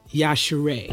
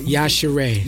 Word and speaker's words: Yashere: But Yashere. – You did well. Just think Yashere. – Yashere. – Yeah. – But Nigerians pronounce it much more aggressively. Okay. Yashere. That's how it's supposed to be pronounced.